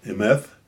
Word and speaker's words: Emet: But when you start to think about it, and I'm going to try to say that, Emet.